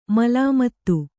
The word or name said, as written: ಮೇಲ್ಮಿತಿ